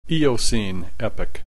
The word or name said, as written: Eocene Epoch